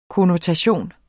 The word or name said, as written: konnotation